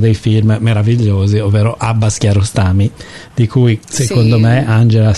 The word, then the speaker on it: Kiarostami: Che si dovrebbe dire Kiarostami, perché in farsi la pronuncia, l'ultima parola è sempre accentata alla fine.